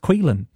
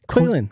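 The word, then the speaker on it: Caoilfhionn: C-A-O-I-L-F-H-I-O-N-N. C-A-O-I-L-F-H-I-O-N-N. And that name is pronounced Quilin. – Quilin.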